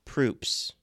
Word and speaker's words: Proops: Greg Proops or Props. I think you pronounce it Props, but it's spelt Proops. – Oh, well, if you say it the same as you would the guy from Survivor, it's Proops.